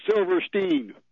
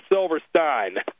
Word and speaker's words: Silverstein: Whenever it's S-T-E-I-N, that's a German, and the Germans pronounce it Stein. Well, the Yiddish don't like to pronounce their names in a German fashion, even if it's spelled that way, so it's always, they pronounce their names Stein, Goldstein, Silverstein, etc. – Okay, well, he spells his name S-I-L-V-E-R-S-T-E-I-N.